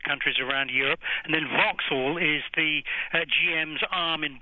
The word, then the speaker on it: Vauxhall: And we remind ourselves, GM Europe owns Opel and Vauxhall, have I said that correctly? – That's correct.